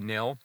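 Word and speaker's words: Nhill: And I hope I'm pronouncing that correctly, Nil. It's N-H-I-L-L. I believe it would be Nil.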